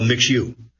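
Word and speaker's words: Mixue: And competitors are always nipping at their heels, particularly for MixU, where a sluggish Chinese economy has led to cutthroat price wars. Last year, MixU, pronounced Mixu, assembled managers and franchises in Singapore, said people familiar with the gathering, having expanded across Asia and Australia.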